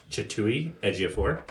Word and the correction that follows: Chiwetel Ejiofor: Chiwetel Ejiofor.